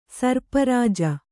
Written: ಸರ್ಪರಾಜ

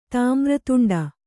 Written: ತಾಮ್ರತುಂಡ

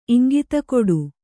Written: ಇಂಗಿತಕೊಡು

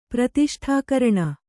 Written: ಪ್ರತಿಷ್ಠಾಕರಣ

